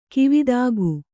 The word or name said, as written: ಗವೇಧುಕೆ